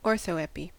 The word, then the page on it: orthoepy: American English